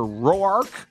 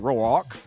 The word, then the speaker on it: Roark: A 5:40 start tonight on the Score with Kyle Hendricks going up against Tanner Roark. Or Rourke, depending on how you say it, Roark.